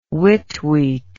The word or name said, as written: Whit week